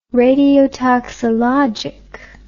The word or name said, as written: radiotoxologic